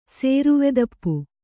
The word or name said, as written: ಸೇರುವೆದಪ್ಪು